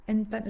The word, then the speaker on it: handpan: Tu es aussi joueur et professeur de handpan. Handpan, j'arrive pas. Comment on prononce Ah, c'est pas mal. Handpan, ouais.